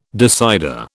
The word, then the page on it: decider: British English